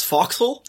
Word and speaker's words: forecastle: It's like that time I pronounced it forecastle when it's foxhole.